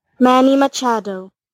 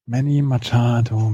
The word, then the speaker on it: Manny Machado: How to pronounce Manny Machado. Ah. Jetzt höre ich es nicht. Manny Machado. Machado. Zack. Manny Machado, Manny Machado.